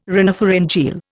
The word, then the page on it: rhinopharyngeal: Indian